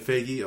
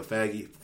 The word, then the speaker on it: Feige: And they're also announced, Kevin Feige or Faggy. – Feige, I think.